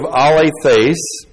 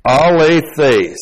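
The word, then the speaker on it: alethes (ἀληθής): This is the accusative singular of the adjective alethes, modifying the accusative singular noun karis plus the genitive singular of theos with the definite article. So this alethes. A-L, long E-T-H, long E-S. Alethes.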